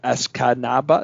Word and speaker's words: Escanaba: But nonetheless, fellas, Dave McCaig, EJ Russell from Escanaba, three syllables there. Escanaba, that's four.